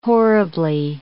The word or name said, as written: horribly